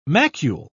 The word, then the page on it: macule: British English